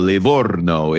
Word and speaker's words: Livorno: And I, by the way, I'm so impressed that you can roll your R's like that because you didn't learn that in Menlo. You know, you didn't learn that in California. So the fact that you can go Livorno is just beautiful.